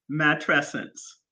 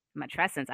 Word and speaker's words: Matrescence: But, and I don't want to say this wrong, is it Matrescence? – Matrescence. – Matrescence. All right, great.